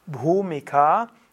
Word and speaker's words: Bhumika: Bhumika sind eigentlich die Stufen und Bhumikas gibt es Bhumika, also BH, H ist auszusprechen, U ist lang, A ist lang.